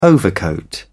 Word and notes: overcoat: British English

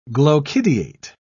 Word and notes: glochidiate: British English

